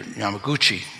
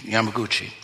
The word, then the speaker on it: Yamaguchi: Now, I was reading about a man by the name of Yamachachi. I'm saying this, butchering his name a little, I think. Yamachachi or Yamaguchi. Yamaguchi.